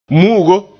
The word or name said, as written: mu'ugo